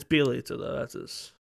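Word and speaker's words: Bjelica: Bilagica? How do you pronounce his name? I don't even know. – Bielitsa. – Yeah, that guy. – Bielitsa.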